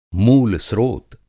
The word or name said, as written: मूलस्त्रोत